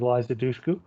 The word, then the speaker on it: Liza Dushku: He directed the original Wrong Turn with Liza Dushku. I hope I'm pronouncing her name right. Could be. – That is correct, yeah.